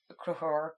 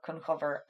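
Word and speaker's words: Conchobar: And that's a story that concerns Cruchor, Cunchover, and that being two pronunciations of the same name.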